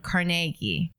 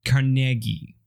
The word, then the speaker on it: Carnegie: No, I think that's how you're supposed to say it as Carnegie, but everyone mispronounced it to Carnegie.